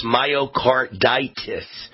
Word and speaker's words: myocarditis: They've told me how you pronounce heart inflammation. It's myocarditis. Myocarditis.